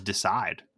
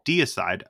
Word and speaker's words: Deicide: And ask me how long I pronounced their name as Decide, because I didn't read it. – Right. – I thought they were Decide. And then when I got into it, it was like, oh, Deicide.